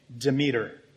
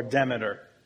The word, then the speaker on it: Demeter: Well, there's this Greek goddess named Demeter, or Demeter. It's one pronunciation or the other, depending on whether you're saying it in Greek or Latin. The emphasis is on the first or last syllable.